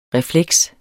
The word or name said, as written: refleks